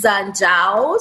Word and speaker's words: Njau: So my last name is Zhao. So the N is silent. It's N-J-A-U.